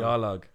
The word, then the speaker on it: Yawlag: Pronounce it, because I got it wrong. – Yawlag.